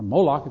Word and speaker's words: Molech: burning their children in a burnt offering to a Molech, or Moloch if you want to pronounce that way.